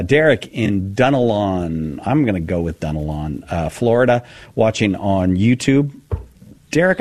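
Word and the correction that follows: Dunnellon: No, actually, it's pronounced Dunellen.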